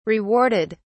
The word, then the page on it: rewarded: British English